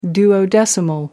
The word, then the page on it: duodecimal: American English